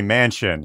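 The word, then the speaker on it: Manchin: Is literally Mansion. His name is pronounced Mansion. Joe Mansion.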